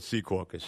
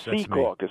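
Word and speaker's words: Secaucus: I call it Sea Caucus. – Sea Caucus. Well, you're a New Jersey person, so you would know.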